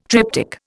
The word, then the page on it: triptych: Indian